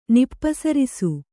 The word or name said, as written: ನಿಪ್ಪಸರಿಸು